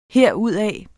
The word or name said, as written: herudaf